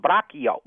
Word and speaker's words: braccio: Well, in Italian it's braccio. – Braccio. – B-R-A-C-H-I-O. It means your arm. – Oh, B-R-A-C-C-I-O. Braccio. Yeah, arm. – C-C-I-O.